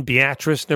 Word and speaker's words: Beatrice: Yes, this was held in Beatrice, Nebraska, and that is how you pronounce it. It's spelled like Beatrice, but it's pronounced Beatrice.